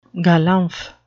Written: galumph

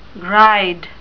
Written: gride

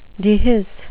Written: dehisce

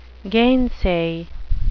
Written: gainsay

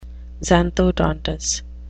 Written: xanthodontous

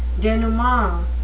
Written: denouement

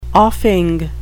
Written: offing

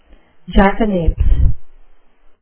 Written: jackanapes

